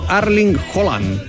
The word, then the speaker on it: Erling Haaland: La pronunciación correcta de el golpeador noruego debe sonar como Erling Haaland.